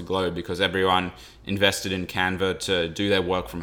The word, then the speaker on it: Canva: So I use Canvas for a lot of things. I'm sure a lot of other... – Sorry, Canvas? – Canva. – Canva. It's the Australian pronunciation.